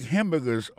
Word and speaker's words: hamburger: On a remarqué que dans le mot hamburger, ça a comme d'habitude, il y a trois syllabes là-dedans. Quand on appuie et puis plus ça, c'est sous premier syllabe là.